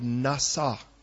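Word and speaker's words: nasa (נשא): The word in Hebrew for forgive is the word nasa. Try that, nasa. We might even spell it in our language, N-A-S-A, and it literally means to lift off.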